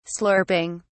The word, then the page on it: slurping: British English